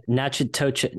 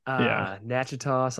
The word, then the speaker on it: Natchitoches: Natchitoches. – It's not even close. It's like, very French. – Natchitoches, something like that.